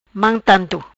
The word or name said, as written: Mam tanto